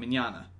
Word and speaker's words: Mignogna: He said it's like Italian, Mignogna.